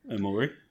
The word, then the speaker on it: Omori: No, I don't know where you're getting that extra syllable from. – Okay, what? How do you say it then? – Omori. – Okay. Omori.